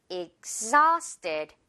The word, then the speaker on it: exhausted: 여기 가운데쯤에 있는 H는 생략하고요. 발음상 exhausted 그대로 이어서 발음해주면 돼요.